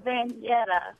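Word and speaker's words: Vanyetta: And instead of the way it's pronounced, it's spelled V as in Victor, A-N-Y-E-T-T-A. – Vanjetta. – But it gets butchered. – Oh, yeah, of course it does. – Like the add and subtract letters. Mm-hmm. So I get Vendetta, Veretta, and Vachetta. Those are the three most popular mispronunciations.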